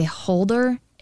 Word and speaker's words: Hulder: But it's called a holder, H-U-L-D-E-R.